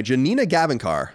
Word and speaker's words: Janina Gavankar: In this clip, actress, and I think I say her name right, Janina Gavankar, I think that's how you say it.